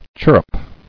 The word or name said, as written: Chirruping